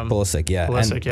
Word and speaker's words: Pulisic: And it's like the last guy before Turner that I saw that got transferred overseas was Pulisic. I don't know how to pronounce the last name, but Pulisic.